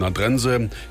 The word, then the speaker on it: Nadrensee: Ganz im Süden Vorpommerns an der polnischen Grenze liegt Nadrense, hinten mit Doppel-E geschrieben und von denen, die es nicht wissen, dann auch gerne mal Nadrensee ausgesprochen. Es heißt aber Nadrense.